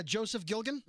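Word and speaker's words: Joseph Gilgun: Joseph Gilgan? – Mm-hmm.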